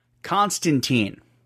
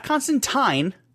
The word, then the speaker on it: Constantine: Constantine, not Constantine, which is what you sacks of shit keep calling him.